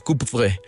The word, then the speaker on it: Coupvray: Sí, exactamente, se pronuncia más o menos Coupvray.